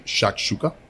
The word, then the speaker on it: shakshuka: Green shakshuka.